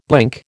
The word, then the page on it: blink: American English